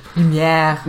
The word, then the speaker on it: Lumière: Und genauso halt Ewan McGregor in seiner Rolle als Lumiere oder wie man das ausspricht. – Lumière. genau so spricht man es aus, da hört ihr es.